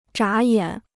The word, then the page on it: 眨眼: zhǎ yǎn